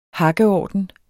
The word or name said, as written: hakkeorden